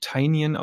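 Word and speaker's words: Tynion: Dark Knights Death Metal war ein großes Thema und auch der Joker War ähm, und ich habe jetzt gelernt, dass der Mann tatsächlich Tinyan ausgesprochen wird.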